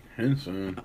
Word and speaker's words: Henson: Now, Taraji P. Hence, what's her name? Taraji P. – Henson. – Okay. I said it wrong.